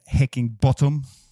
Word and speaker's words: Heckingbottom: Och mannen med det sexiga namnet Paul Heckingbottom, eh, var det korrekt uttal Gustav? Klockrent alltså.